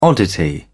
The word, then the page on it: oddity: British English